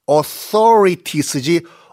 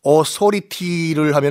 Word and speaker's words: authorities: 그리고 th 발음하는 거 잊지 마세요. authorities지 authority를 하면은 오소리가 t를 내는 거죠.